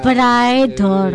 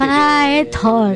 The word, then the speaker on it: Praetor: me gustó esa banda y se pronunciaba Praetor. – El gordito este Praetor.